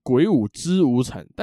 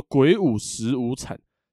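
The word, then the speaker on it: 鬼舞辻无惨: 这个中间这个字呢，常常会被念错，会念成很多的人会念成果汁的汁，然后念成鬼舞之无惨，但其实汁是不对的哦。这个字呢念十，叫做鬼舞十无惨。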